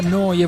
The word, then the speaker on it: Neu: Neuradio.it, si scrive Neu, si pronuncia Noi e vuol dire nuova, nuova emittente urbana in diretta da Bologna, come detto, 24 ore su 24, 7 giorni su 7.